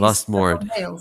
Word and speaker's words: Lustmord: Lustmord. – That's perfect. – Okay. – That's how he would pronounce it himself because he's Lustmord.